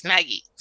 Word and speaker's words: Maggie: two syllables, Maggie.